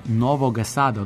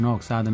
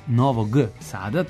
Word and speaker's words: Novog Sada: Stevan iz Novog Sada je rekao da mu Novoga Sada, odnosno izgovaranje reči Novoga Sada umesto Novog Sada mesecima para sluh i da se pravilno izgovara iz Novog Sada, tako da eto, nema naravno na čemu, trudimo se da izgovaramo onako kako treba.